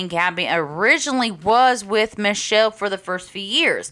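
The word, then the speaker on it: Mitchelle: Yeah, it's pronounced Michelle, but it's M-I-T-C-H-E-L-L-E.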